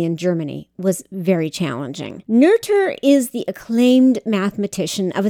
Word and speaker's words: Noether: Her name looks like it should be pronounced Noether, N-O-E-T-H-E-R, but it's actually pronounced Noether.